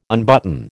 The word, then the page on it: unbutton: American English